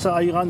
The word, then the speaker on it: ayran: Ayran. – Ayran, pardon, je prononce mal.